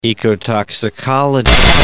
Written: ecotoxicology

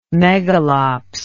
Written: megalops

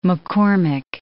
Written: McCormick

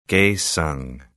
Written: Kaesong

